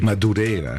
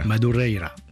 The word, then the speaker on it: Madureira: Sustrak, votre version de la Rua Madureira, je l'ai mal prononcée, mais j'ai essayé. – Madureira. – Madureira.